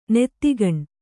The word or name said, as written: ನೆತ್ತಿಗಣ್